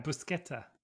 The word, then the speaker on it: Buscetta: You were saying it right. Buscetta.